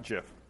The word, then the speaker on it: GIF: I think technically it's supposed to be pronounced JIF, but you just sound like a dick when you say it that way.